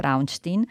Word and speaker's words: Braunstein: Il titolo del pezzo di Amedeo Feniello, che recensisce un libro di uno storico francese, Philippe Braunstein. Dico Braunstein perché probabilmente in Francia lo pronuncio così, ma naturalmente se lo leggessero in Germania sarebbe Braunstein, ma è appunto uno storico francese.